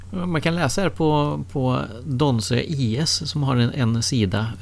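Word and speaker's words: Donsö: Jag någon gång bara helt vilse ute i Göteborgs skärgård och inser att jag är nära Donse, som man uttalar det.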